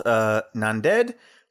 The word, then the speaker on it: Nanded: It's close to a place called Nanded or Akola.